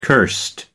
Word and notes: cursed: American English

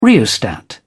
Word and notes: rheostat: British English

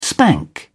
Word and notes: spank: British English

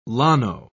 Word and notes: llano: British English

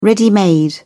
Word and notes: ready-made: British English

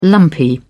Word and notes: lumpy: British English

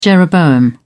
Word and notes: jeroboam: British English

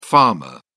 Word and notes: pharma: British English

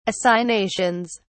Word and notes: assignations: British English

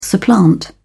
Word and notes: supplant: British English

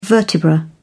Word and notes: vertebra: British English

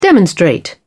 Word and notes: demonstrate: British English

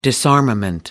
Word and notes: disarmament: American English

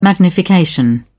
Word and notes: magnification: British English

